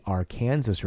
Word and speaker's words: Arkansas: we refer to as the Arkansas River. – And we even have a city that would be Arkansas City, but it's Arkansas City. – Yeah, because it's ours, as the locals say, which I just kind of shake my head and walk away when I literally think there are people that live here.